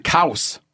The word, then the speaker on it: Couse: Chris Denham read something Richard Ockenfels wrote, and Richard Ockenfels writes in his annotated bibliography of Kooz Whitetail, he writes, it rhymes with house.